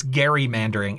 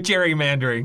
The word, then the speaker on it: gerrymandering: Apparently, the guy who did it, his name was Gary, not Jerry. So there you go. – Well, that's how it's spelled, but that's not how it's been pronounced. – I know. I'm sticking with gerrymandering because that's, you know, I'm old school like that.